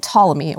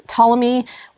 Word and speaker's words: Ptolemy: He had a contemporary called Ptolemy, or Ptolemy, if you can't pronounce silent letters.